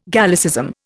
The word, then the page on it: Gallicism: Indian